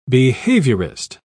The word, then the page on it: behaviorist: British English